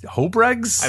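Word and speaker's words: Houbregs: And then Bob, I never knew how to pronounce his name. Was it Hobregs? Hobregs? – I believe so, yes.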